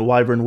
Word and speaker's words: wyvern: Now, also, the Leeds family had a family crest, which was basically a wyvern, which is, you know, wyvern, wyvern, both are correct pronunciations.